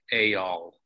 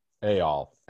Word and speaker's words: Eyal: Uh, think of the E as an A, Ayal. Ayal, Ayal Press.